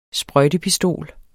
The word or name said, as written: sprøjtepistol